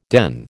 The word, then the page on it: den: American English